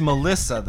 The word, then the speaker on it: M3LL155X: M3LL155X, pronounced Melissa, though.